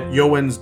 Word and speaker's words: Eeyowens: And you guys should check her out at Yowens Doodles. Again, that is E-E-Y-O-W-E-N-S. Doodles. It doesn't sound at all it's spelt like, but it is fantastic work.